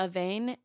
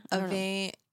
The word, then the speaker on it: Avène: I use, I don't know how to say it, Avène, A-V-E-N-E. It's like a French brand, but it has an accent over the E. – Yeah. – Avène.